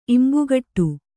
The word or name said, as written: ಇಂಬುಕೊಡು